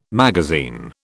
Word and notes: magazine: British English

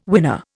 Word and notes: winner: Indian

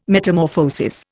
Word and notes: Metamorphosis: Indian

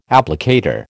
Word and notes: applicator: American English

